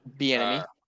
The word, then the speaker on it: Bieniemy: I don't remember how to say his name. – Bieniemy.